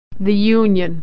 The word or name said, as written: the union